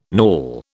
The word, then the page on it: knawel: British English